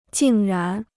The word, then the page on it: 竟然: jìng rán